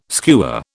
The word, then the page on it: skewer: British English